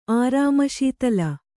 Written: ಆರಾಮಶೀತಲ

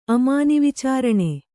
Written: ಅಮಾನಿವಿಚಾರಣೆ

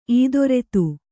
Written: ಇದಿರೆತ್ತು

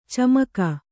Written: ಚಮಕ

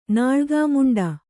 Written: ನಾಳ್ಗಾಮುಂಡ

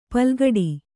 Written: ಪಲ್ಕಡಿ

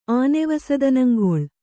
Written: ಆನೆವಸದನಂಗೊಳ್

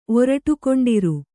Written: ಒರಟುಕೊಂಡಿರು

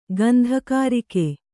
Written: ಗಂಧಕಾರಿಕೆ

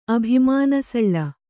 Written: ಅಭಿಮಾನಸಳ್ಳ

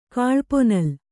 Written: ಕಾಳ್ಪೊನಲ್

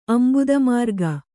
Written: ಅಂಭೋದಮಾರ್ಗ

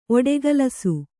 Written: ಒಡಗಲಸು

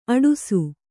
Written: ಅಟ್ಟಸ

